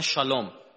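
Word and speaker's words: شالوم (שלום): میدونی دیگه عبری ها سه و شه تلفظ میکنن عمدتاً. موسی را میگن موشه، سلام را میگن شالوم، سلیمان را میگن شلمان، نسا زنا را میگن نشا.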